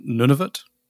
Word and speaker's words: Nunavut: now, tell me about the pronunciation. Nunavut? – That's correct, yeah. – Nunavut. Yay, there we go.